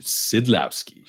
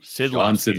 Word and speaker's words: Szydlowski: There's big news with the Komets in terms of signing Shawn Slizdowski, bringing him back. What kind of impact is that going to have on them moving forward? – Boy, we need to work on that pronunciation. Szydlowski. Szydlowski. Shawn That's a hard one.